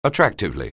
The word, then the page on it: attractively: anglais